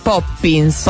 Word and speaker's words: Poppins: La pronuncia esatta è Mary Poppins.